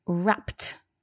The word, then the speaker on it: wrapped: And now we've got wrapped. And so the reason for this is because the p sound at the end of wrap, it needs us to make a t sound.